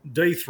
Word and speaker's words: Death: Oh, I remember that they had the Deeth Row, or Deeth Row, as you pronounce it.